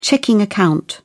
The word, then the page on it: checking account: British English